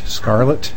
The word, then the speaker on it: Scarlett: Yes, Mr. Chairman, my name is Robert Scarlett, S-C-A-R-L-E-T-T, as in O'Hara.